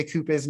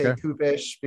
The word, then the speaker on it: Kupisz: I don't know how to say it exactly. I say Kupisz now. Before I got married, I say Kupisz because I was told by my father the right Polish way of saying it is Kupisz, but then I talked to native Polish speakers.